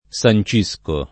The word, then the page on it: sancire: lingua italiana